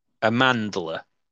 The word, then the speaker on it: Amandla: And so this stars Amanda Stenberg. I feel like that's not the right way to pronounce it, but Who? – Amanda. – Amandla.